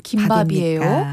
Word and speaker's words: 김밥: 표준 발음은 김밥이에요. 이렇게 문의를 하시면 그때 당시에 드릴 수 있는 최선의 답변은 아, 김과 밥이 결합한 합성어지만 사이소리 현상이 일어나지 않아서 그렇게 정해진 것입니다.